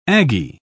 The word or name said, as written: aggie